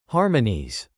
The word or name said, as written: harmonies